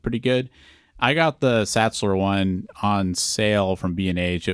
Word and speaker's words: Sachtler: Is it Satchler? – It is Satchler, yeah. I guess that's how you pronounce that.